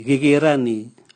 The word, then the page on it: gigirani: Raga